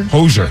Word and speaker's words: Hozier: I never know how to pronounce his name. Is it Hozier? – Hozier. – Hozier. Okay.